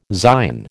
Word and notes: Zion: American English

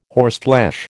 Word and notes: horseflesh: American English

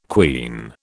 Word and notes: quean: British English

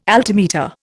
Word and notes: altimeter: Indian

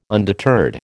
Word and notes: undeterred: American English

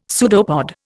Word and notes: pseudopod: Indian

